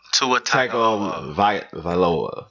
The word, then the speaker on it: Tua Tagovailoa: How do you say his name? – Tua Tagovailoa? – Tua Tagovailoa. Yes.